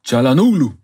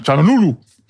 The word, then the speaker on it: Çalhanoğlu: Çalhanoğlu. – Ja, Çalhanoğlu.